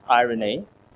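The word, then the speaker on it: irene (εἰρήνη): The Greek word is pronounced irene, and it means to join or to make whole.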